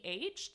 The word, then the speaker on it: Fernweh: Does the German word Fernweh mean anything to you? – You say it like a native speaking German. I can tell you that. – French is my specialty, but it's Fernweh.